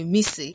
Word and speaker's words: Missy: My name is Bliss Akinyemi, but I prefer that you call me Missy. Missy is spelled M-I-S-S-Y and pronounced M-I-S-S-Y.